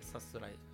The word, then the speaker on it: さすらい: はいはい、あ、出航と書いてさすらい、アルファベット表記でさすらい。